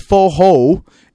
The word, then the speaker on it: forró: Like I think the pronunciation is kind of like, it's like you have an age. So yeah, if you think in English, it's F-O-H-O, yeah. But instead of forró, you put that little accent at the last O and it becomes forró.